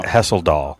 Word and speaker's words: Hesseldahl: Hesseldahl. – Hesseldahl. Like, yep. Like, what a doll.